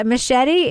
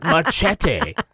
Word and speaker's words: machete: By the way, machete is also a word in English. Just FYI. – not machete. Machete. You have to say it right.